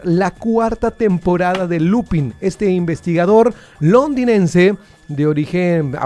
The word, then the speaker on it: Lupin: Es Lupin. – Ah, Lupin. – Lupin. – Estamos en Yucatán. – Se escribe, Lupin, pero ya vi que es Lupin.